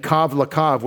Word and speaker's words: kav lakav: Tzav latzav, tzav latzav, and kav lakav, which rhymes with that, kav lakav, which we think is translated line on line, could be string for string.